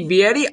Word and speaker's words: Vietti: You can follow him on Twitter, at Danny Vietti. I will spell that last name for you, V-I-E-T-T-I, as it is Danny Vietti joining me on the podcast.